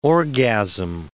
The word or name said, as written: orgasm